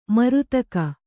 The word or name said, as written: ಮರೆದೆಗೆ